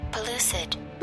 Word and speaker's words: pellucid: So let me say it correctly. – Pellucid.